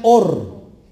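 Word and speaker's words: Or (אור): Y se pronuncia Or. Luz en hebreo es or.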